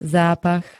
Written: zápach

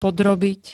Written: podrobiť

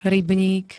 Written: rybník

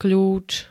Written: kľúč

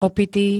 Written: opitý

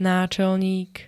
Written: náčelník

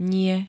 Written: nie

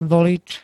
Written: volič

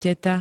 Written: teta

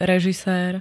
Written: režisér

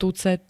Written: tucet

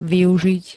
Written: využiť

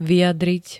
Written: vyjadriť

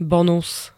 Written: bonus

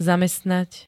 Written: zamestnať

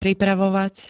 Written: pripravovať

